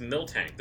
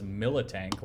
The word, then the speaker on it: Miltank: I think it's just Miltank. There's no second I, is there? – Oh, man, I thought there was. – Yeah, it's just Miltank. Well, there we go. I've been... Very much like other mispronunciations that have just become like mental canon for us. Like, I've been thinking it was Militank, like military, because it's a tank.